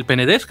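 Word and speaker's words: Penedès: Eh, entonces me mencionaba que el nombre de Rugby era eh, Club Villafranca del, del Penedés, creo que se pronuncia. Sí, exactamente, sí señor, sí.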